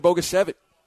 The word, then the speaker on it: Bogusevic: Yeah, there was an H at the end of that. – No. Brian Bogusevic with a C. So the H is silent, or the H is implied.